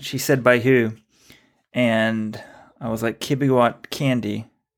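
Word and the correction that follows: Kandie: which is how we pronounce his name. Wrong on the Malcolm Gladwell podcast as well. It's Candier, we now know, but I didn't even know the correct name at the time.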